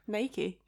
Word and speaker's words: Nike: How do you say it, actually? – Nike.